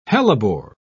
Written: hellebore

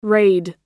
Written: rayed

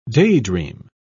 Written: daydream

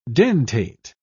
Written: dentate

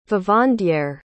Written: vivandière

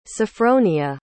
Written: sofronia

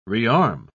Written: rearm

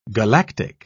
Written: galactic

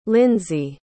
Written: lindsay

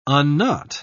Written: unknot

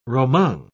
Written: roman

